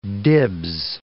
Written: dibs

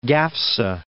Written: Gafsa

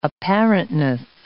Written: apparentness

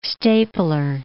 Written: stapler

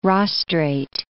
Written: rostrate

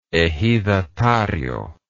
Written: ejidatario

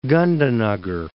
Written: Gandhinagar

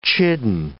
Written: chidden

